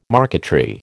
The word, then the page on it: marquetry: American English